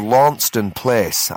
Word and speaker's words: Launceston Place: So we've come down here to Launceston Place. I've just been told that's the correct pronunciation.